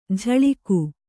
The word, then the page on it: ಝಳಿಕು: Kannada